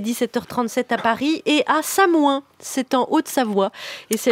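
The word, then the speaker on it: Samoëns: Alors, il paraît qu'on ne prononce pas le S. Non, il paraît qu'on ne dit pas le S. – J'ai habité en Haute-Savoie 8 ans, enfant, mon cher ami. – C'est marqué sur Wikipédia, on ne prononce pas le S. – Non, on dit Samoin.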